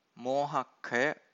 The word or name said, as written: mohakkhaya